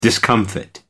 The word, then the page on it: discomfit: American English